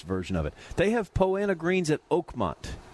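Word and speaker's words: Poa annua: It's spelled Poa Anua. Some people say Poa Anua. I don't care. – I've heard it both ways.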